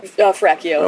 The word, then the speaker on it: Ferracchio: Is it Ferracchio or Ferraccio? – Ferracchio. Okay. I always call him Ferraccio, too. He's like, no, it's Ferracchio. I'm like, oh, my bad. – Because it rhymes with Ralph Macchio.